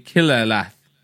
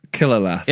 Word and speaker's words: Qilalath: So the name is going to be Quillalath. I'll post it in the Discord so you can see how this is spelled. It's spelled, for those listening, Q-I-L-A-L-A-T-H. – Quillalath.